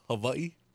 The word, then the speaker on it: Hawaii: There's the real way is like Hawaii. – Really? – Yeah, that's... – Put a V in there? – Yeah, because that's how a W-A-I is pronounced in the Hawaiian language.